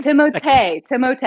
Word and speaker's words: Timothée: That's closer to how it's actually supposed to be pronounced. – No. Really? – Timothée, at least. Not Chalamet part, but yeah.